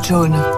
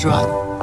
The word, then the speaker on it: Joan: Je m'appelle John, John Vera. Ça s'écrit J-O-A-N. Mais j'ai horreur qu'on dise Johan.